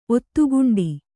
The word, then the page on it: ಒತ್ತುಗುಂಡಿ: ottuguṇḍi